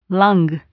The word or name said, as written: lung